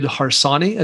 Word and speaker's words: Harsanyi: And I'm going to recommend a book called First Freedom, spelled out FIRST, by David Harsanyi, I think is how you say his name.